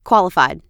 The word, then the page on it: qualified: American English